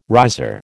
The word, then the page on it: riser: American English